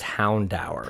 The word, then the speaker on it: Houndour: The official pronunciation is Houndour.